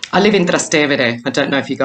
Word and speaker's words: Trastevere: And Trastevere, because that's the way you pronounce it, because it's a bit of a tongue, tie for so many people.